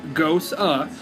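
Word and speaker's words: Gose: So next up, we've got the Gosei. It is lime-infused, salty sour. – Somewhere it was pronounced Gosa. – Whatever. – Gosa. – It says, and it's 5% alcohol by volume. Gosa, pronounced like Gosa, is an ancient sour and saltine-tasting ale.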